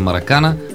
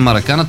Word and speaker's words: Маракана: Защото всички знаят, че е Маракана. – Ами на времето да се сложили ударението където трябва. Те въобще не са слагали ударение.